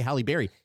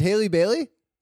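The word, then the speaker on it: Halle Bailey: It's so hard to say her name because I want to say Halle Berry. Haley Bailey. – It's Haley Bailey.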